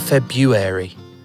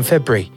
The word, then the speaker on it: February: You're just going February. You're not pronouncing it right. It's February. But you just go February, don't you? – February, yes, like an apostrophe there.